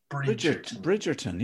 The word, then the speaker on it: Bridgerton: Anyway, embarrassingly, on a number of levels, I am and have been addicted to Bridgerton on Netflix. I can't say it properly. – Bridgerton, yeah.